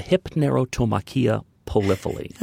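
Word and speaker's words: Hypnerotomachia Poliphili: Let's talk about, now I'm going to try to say this correctly. I don't want to butcher it too badly. Hypnerotomachia polypali? Polypali. – That's very close. That's very good.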